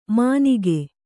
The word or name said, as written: ಮಾನಿಕೆ